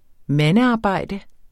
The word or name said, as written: mandearbejde